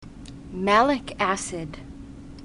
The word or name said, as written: malic acid